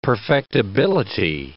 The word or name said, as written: perfectibility